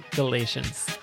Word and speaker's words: Galatians: It's good time up in here discussing Galatians. That's not easy to say. – Discussing Galatians.